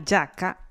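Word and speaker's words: giacca: Ok, en italiano la yaca es una chaqueta y se pronuncia así, la yaca.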